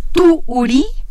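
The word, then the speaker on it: Tu'uri: Tu Uri. – Tu Con acento en la primera U.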